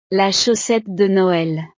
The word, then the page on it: la chaussette de noel: frances